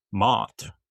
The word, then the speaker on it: Mott: Here comes the Mott envelope. Pronounced like doormat.